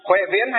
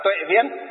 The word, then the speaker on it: Huệ Viễn: Huệ Viễn hay còn gọi là Tuệ Viễn, ở trong miền Nam họ đọc là Huệ, còn Việt Nam chúng ta, ngoài Bắc chúng ta đọc là Tuệ,